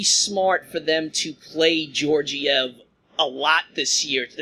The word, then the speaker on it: Georgiev: Now, I'm going to assume, it's Gorgiev or Georgiev, how do you pronounce it? – Well, great question. – Let's go to Ryan. – Ryan? – Great question. – Since Ryan doesn't pronounce R's. – I'll go with Georgiev. – Yeah, Georgiev, yes.